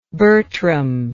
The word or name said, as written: Bertram